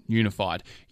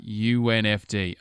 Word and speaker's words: UNFD: And yes, it's pronounced as an initialism, UNFD, not unified.